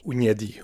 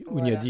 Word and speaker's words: Hunyadi: Ougnadi, oui. – Ougnadi, c'est pas comme ça que ça s'écrit, non, alors non. – Voilà. – Ça s'écrit H-U-N-Y-A-D-I. Ouniadi, voilà.